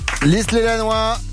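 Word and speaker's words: Lys-lez-Lannoy: Lys-les-Lannois, c'est bien prononcé Stéphane, on dit comme ça? – Oui, c'est ça, ouais.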